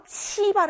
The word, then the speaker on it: 七: 키 아니고 예외, 요거, 요거, 요거 기억하라고 했어요. 치 발음이었습니다. 치. 치, 숫자 7이에요. 숫자 7에서 리을만 빼면 돼요. 치입니다. 치, 일성이고요.